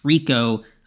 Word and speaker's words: Rico: I'm finally pronouncing his name right. I was saying Rekow before. It's Rico, just spelled a little funky there.